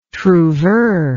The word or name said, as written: trouveur